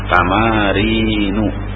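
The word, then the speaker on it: تمارين: Samarin, samarinu. Jangan baca salah. Tamarinu bacanya tamarinu, artinya apa?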